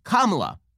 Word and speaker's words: Kamala: When top Republicans, including President Donald Trump and Vice President Mike Pence, mispronounced Democratic vice presidential nominee Kamala Harris's first name, it's not just disrespectful, it's racist, according to the Democrats because it's pronounced Kamala, not Kamala.